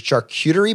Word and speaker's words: charcuterie: help me with this word, is a charcuterie board? – Yeah, that's right. – Did I say it right? – Yeah, perfectly.